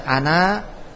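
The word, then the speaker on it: ana (أنا): Andaikan alif itu dibaca, maka dibacanya, andaikan alif itu berpengaruh, maka dibaca ana fi masjidikum. Namun apa? Alif di situ tambahan. Alif di situ tambahan, keberadaannya seperti tidak ada, maka dibaca pendek ana fi masjidikum.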